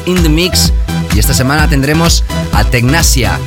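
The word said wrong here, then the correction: Technasia: Tecnicia para pronunciarlo más correctamente, será nuestro invitado en los últimos 30 minutos de este show.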